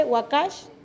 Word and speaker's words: Wakash: Las palabras claves es, donde sembraron, Wakash es su nombre del ganado, así se pronuncia en guave, Wakash, en español ganado, Takoch, cortó, o sea, Taj, que es el cuero.